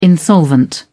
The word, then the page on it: insolvent: British English